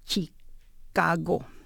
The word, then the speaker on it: Chicago: So mom's face went blank, and she turns to my dad and says, what is Chicago? It's the first time she pronounced a CH with a ch sound. I said, no, mom, it's not ch, it's Chicago.